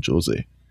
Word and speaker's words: José: Ja, er ist Portugiese. Er wird es nicht José ausgesprochen, sondern Jose.